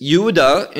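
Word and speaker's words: Yehuda (יהודה): And Judah, this is Yehuda in Hebrew, that's how we pronounce it.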